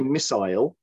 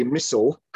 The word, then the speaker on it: missile: You use that a word in the title, um, which is kind of the biggest difference between British and American pronunciation, which I, I would say missile, and I think you say missile.